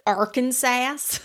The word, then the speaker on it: Arkansas: Actually pronounced Arkansas. – Arkansas. You're right. I apologize.